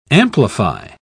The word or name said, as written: amplify